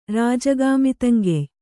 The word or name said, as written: ರಾಜಗಾಮಿತಂಗೆಯ್